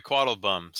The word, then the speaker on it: Quattlebaum: You mispronounced his name, by the way. – Oh, sorry, Quattlebaum. Sorry, Quattlebaum. It's my accent.